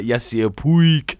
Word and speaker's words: Yasiel Puig: Yeah, Yasiel Puig.